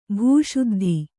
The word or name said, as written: ಭೂಶುದ್ಧಿ